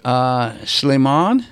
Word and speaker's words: Shlemon: Shlemon. – That's good enough. – S-H-L-E-M-O-N.